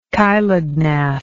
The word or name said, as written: chilognathous